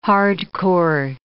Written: hardcore

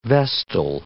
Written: vestal

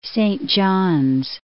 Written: Saint John's

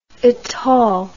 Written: itol

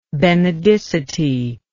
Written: benedicite

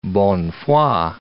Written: bonne foi

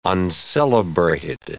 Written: uncelebrated

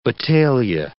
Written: battalia

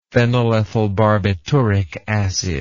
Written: phenylethylbarbituric acid

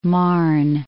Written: Marne

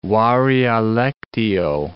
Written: varia lectio

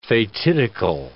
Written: fatidical

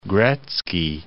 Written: Gretzky

